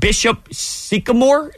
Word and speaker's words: Bishop Sycamore: Apparently there is a school called Bishop Sycamore.